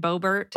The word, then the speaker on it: Boebert: How do you pronounce that lady's name? Bobert? Lauren Boebert.